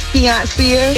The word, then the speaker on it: Fiancia: Hey, my name is Fiancia. I'm calling from West Atlanta. – Say your name again. – It's Fiancia. – Yeah, you know people will tell you your name sounds very close to Beyonce, right? – Yes, ma'am.